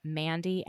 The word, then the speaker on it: Mandy: If you have a difficult time remembering it, you can just type in Mandy, M-A-N-D-Y, and then Slut, S-L-U-T, into...